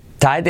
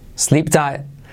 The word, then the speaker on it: tight: Fijaos cómo se pronuncia tight. No se dice tight, sino ta, e y luego te, tight. Y la última T casi no se pronuncia, ¿vale? Es decir, no se diría sleep tight, sino sleep tight.